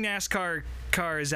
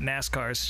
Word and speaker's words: NASCAR: Seeing NASCAR cars outside of, and for all the new fans maybe listening to this, that's how you say it, NASCAR cars, not NASCARs, just putting that out there.